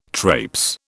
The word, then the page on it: trapes: British English